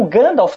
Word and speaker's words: Gandalf: Por exemplo, algumas palavras nas línguas élficas, o F tem um som levemente de V. E o Gandalf, que tem F no final, não tem V.